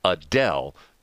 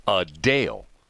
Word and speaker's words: Adele: It's not Adele. It's Adele. Like U-H-D-A-L-E with the accent on the second syllable. Adele. Not Adele.